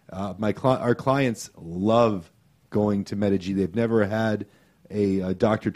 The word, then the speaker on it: Medig: Yeah, Medical Injury Group, otherwise known as Medig, and it's spelled M-E-D-I-G, for those listeners out there. So it's Medig.